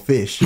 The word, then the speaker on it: Phish: Oh, there's this group in America called Fish. – Yo. – That really is a group. It's spelled P-H-I-S-H.